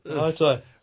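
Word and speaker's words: Ngatai: Charlie Nagatai, Nagatai. – Stop adding a syllable. – Nagatai. – He ignore the G.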